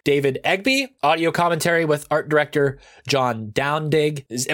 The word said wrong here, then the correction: Dowding: Am I saying that right? – Dowding.